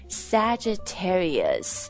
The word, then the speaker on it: Sagittarius: Sagittarius 这个要不同的 syllable 音节跟大家拆分一下、它其中就是 S-A-G-I-T-T-A-R-I-U-S 这个 G 不念 G 而是 J 的音对吧